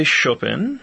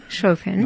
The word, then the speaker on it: Chopin: I say Chopin. – Chopin. – Which is how a lot of Poles say it.